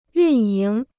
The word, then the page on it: 运营: yùn yíng